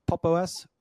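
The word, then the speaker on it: Pop!_OS: So we kind of alluded to, PopOS, is that how you pronounce it? PopOS or how? – It is.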